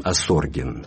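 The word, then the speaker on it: Осоргин: Любопытно, что сейчас в Риме живет священник, настоятель русской церкви римской, с той же фамилией, но с другим ударением, Асоргин, Михаил Асоргин, почти полный тезка.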